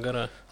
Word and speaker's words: Агора: Да, э, мне позвонили из э, отдела Эй, пригласили на допрос, я вот взял адвокату Агора или Агаре, как там правильное ударение, я все время путаю. Агора. – Агора, mm-hmm.